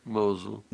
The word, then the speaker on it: Mosul: They lost them in the collapse of, what's it, Mosul? Is that how you say it? Mosul? – Mosul. – Mosul. All right. Mosul.